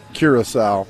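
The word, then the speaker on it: Curaçao: I think of everything that you've done, the fact that you actually pronounce Curacao perfectly. Thank you for that. – You're welcome. – How's Curaco doing? – No, not Curaco.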